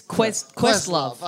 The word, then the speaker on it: Questlove: Is it Questlove or Questlove? – It's Questlove.